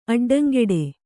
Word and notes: ಅಡ್ಡಂಗಟ್ಟು: aḍḍaŋgaṭṭu

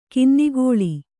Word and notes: ಕನ್ನಗಳ್ಳ: kannagaḷḷa

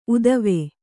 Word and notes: ಊತ್ವ: kannada

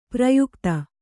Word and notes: ಪ್ರಯುಕ್ತ: prayukta

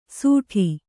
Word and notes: ಸೂಟಿ: sūṭi

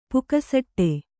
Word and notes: ಪುಕಸಟ್ಟೆ: pukasaṭṭe

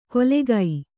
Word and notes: ಕೊಲೆಗೈ: kolegai

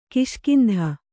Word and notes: ಕಿಷ್ಕಿಂಧ: kiṣkindha

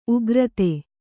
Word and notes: ಉಗುರೊತ್ತು: ugurottu

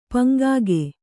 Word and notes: ಪಂಗಿಗ: Kannada

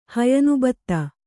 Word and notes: ಹಯನುಬತ್ತ: Kannada

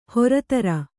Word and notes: ಹರ್ತಾರ: hartāra

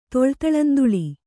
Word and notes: ತೊಳ್ತಳಂದುಳಿ: kannada

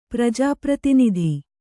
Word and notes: ಪ್ರಜಾಪ್ರತಿನಿಧಿ: prajā pratinidhi

